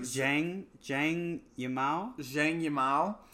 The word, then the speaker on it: Zhang Yimou: It's I know it's like Zhang Yimou.